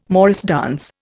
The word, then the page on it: morris dance: Indian